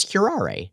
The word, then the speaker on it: curare: Is that how you pronounce that? I always said curare. It's curare. Now I know.